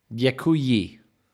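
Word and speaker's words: Děkuji: Dakuyi or ye? – Yakuyi. – Yakuyi.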